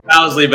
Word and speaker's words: Bousley: Now, Coach, it's Bousley, right? I said that correctly, right? – Bousley, but that's okay.